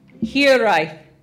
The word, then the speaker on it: hiraeth: Just, it's like here and then rife. Here, rife. And if you can, you know, give it a good. – H-I-R-A-E-T-H.